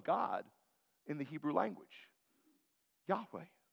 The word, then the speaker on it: Yahweh: In Hebrew, it would probably be pronounced more like Yahweh. In an Anglicanized version, we would say Jehovah.